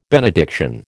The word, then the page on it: benediction: American English